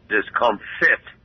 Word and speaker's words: discomfit: Discomfort. There is your word of the day. I think I mispronounced that. Not discomfort. Discomfit. D-I-S-C-O-M-F-I-T. Discomfit. D-I-S-C-O-M-F-I-T.